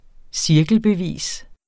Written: cirkelbevis

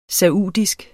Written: saudisk

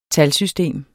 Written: talsystem

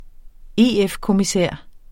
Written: EF-kommissær

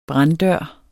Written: branddør

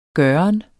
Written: gøren

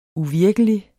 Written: uvirkelig

